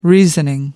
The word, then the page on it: reasoning: American English